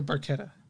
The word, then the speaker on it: Barchetta: Yeah, because what I'm seeing is the second syllable should be covered with a hard K instead of a soft ch. So Barchetta.